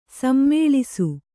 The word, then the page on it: ಸಮುಲ್ಲಾಸ: samullāsa; Kannada